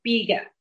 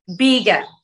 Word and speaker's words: Bilge: No, it's spelled B-I-L-G-E, so it's pronounced Biga. – It's pronounced how? – Biga. B-I-L-G-E. So the I is before the L.